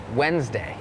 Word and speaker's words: Wednesday: โล่งออกเหรอลูกโล่งใช่ไหมเห็นแล้วถอนหายใจเฮือกเริ่มเลยก่อนเริ่มรายการพี่โบพูดถึงคำว่าอะไรน้องๆจำได้ไหมคะ Silent letters ใช่ไหมคะนั่นก็คือตัวอักษรเสียงแต่อย่างคำนี้ค่ะเป็นคำหนึ่งที่มี silent letters Wednesday it has that D W E D it's not wed Wednesday Wednesday D is silent